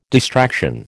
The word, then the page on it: distraction: American English